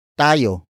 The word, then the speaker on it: tayo: Tayo.